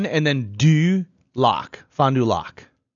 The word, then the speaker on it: Fond du Lac: Fond du Lac. I'm pretty sure I'm saying that right because it's a French.